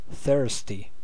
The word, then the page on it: thirsty: /ˈθɜː(ɹ)st.i/